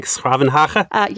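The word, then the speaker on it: 's-Gravenhage: I actually spent a little time in the Netherlands, and I remember that Den Haag is short for, I'm going to butcher it, but something like Schravenhage. – Yes, I think I get that right. – That's perfect pronunciation.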